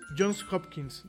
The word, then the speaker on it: Johns Hopkins: Eh, pero es Johns, Johns Hopkins, ¿no? Yo sé que, que es Johns Johns Hopkins. Sí, creo que lo pronuncié, mal.